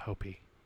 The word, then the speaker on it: Hopi: It's pronounced Hopi. – Hopi. – Please don't say Hopi.